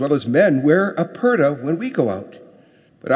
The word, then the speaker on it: purdah: It's spelled P-U-R-D-A-H. It's pronounced purda.